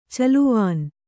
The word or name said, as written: ಚೆಲುವಾನ್